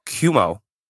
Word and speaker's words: Cuomo: Is it Cuomo? – Cuomo.